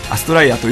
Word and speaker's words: アストライア: それとは、まあ、全く違うとも言い切れないぐらいの感じでは捉えていただきたいんですけども、基本的にはアストラルエアと書いてアストライアと読むという造語として考えておりまして、それは星々の歌というような意味を込めた言葉になっております。